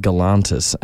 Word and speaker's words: Galantis: It's pronounced Galantis, okay, mate.